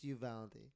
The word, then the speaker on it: Uvalde: No, that's not how we pronounce it. It's just Uvalde.